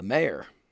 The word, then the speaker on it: mayor: Well, every show I do get my grouchiness about how broadcasters can't pronounce mayor. If a broadcaster can't sees the word M-A-Y-O-R on a script and they pronounce it mayor, M-A-R-E, they should go to where you think Baron Corbin belongs.